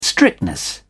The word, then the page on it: strictness: British English